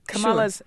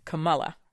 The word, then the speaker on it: Kamala: but it's K-A-M-A-L-A, and you pronounce it Kamala. – Kamala, yeah. – But a lot of people are used to seeing that pronounced Kamala.